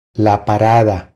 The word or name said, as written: La parada